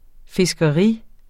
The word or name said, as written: fiskeri